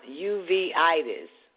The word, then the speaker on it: uveitis: Bird shot chorioretinopathy is BSC, is a type of uveitis, pronounced uveitis.